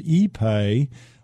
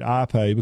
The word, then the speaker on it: ipe: and it's called ipe, or some people call it ipe because it's spelled I-P-E.